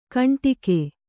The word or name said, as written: ಘುಂಟಕ